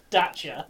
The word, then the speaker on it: Dacia: Well, like we found out that Dacia was actually pronounced Dacia.